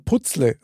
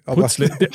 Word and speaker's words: Puzzle: Aber wie spricht man das aus? Puzzle, Puzzle? Mein Papa hat immer gesagt Putzle. – Putzle. Und ganz ehrlich, ich bin auch mit Putzle aufgewachsen.